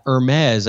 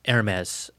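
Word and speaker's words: Hermès: Then there's that Hermes, I think that's how you say it. – Rich, it's Hermes. Hermes.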